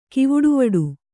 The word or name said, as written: ಕಿವುಡುವಡು